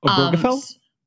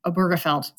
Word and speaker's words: Obergefell: Obergefell? – Thank you, Obergefell.